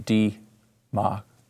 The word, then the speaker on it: democracy: First of all, democracy. D-ma-cra-cy. Four syllables. Not democracy. Not democracy. Not democracy. Say the whole word. Just get through it. It's not that long. It's four syllables.